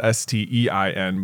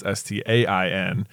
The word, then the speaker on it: Berenstain: Where everyone thinks it's STEIN, but actually it's STAIN.